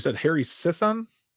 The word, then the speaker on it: Harry Sisson: And it sounds like Harry Sisson?